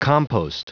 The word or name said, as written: compost